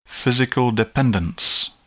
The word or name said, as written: physical dependence